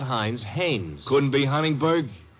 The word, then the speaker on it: Haines: did you say his name is? – Haines. H A I N E S. Ah, oh, Haines.